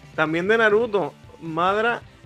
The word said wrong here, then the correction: Madara: Uchiha. – Oh, Madara, Madara, Madara Uchiha.